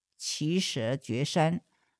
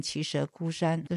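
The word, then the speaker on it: 耆阇崛山: 罗月指是音译，王舍城是意译，所以这里是指的王舍城的奇舍觉山。奇舍觉山有的念作奇舍孤山，根据玄应音译是念奇舍孤山，这是不同的发音。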